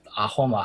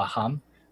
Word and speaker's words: Ahom: They're controlled by this thing called the I don't know how to pronounce it correctly, but Ahom Aham. The S in Assamese is kind of an H sound, is my understanding. So in English, it's written as H AHOM, the Ahom Kingdom.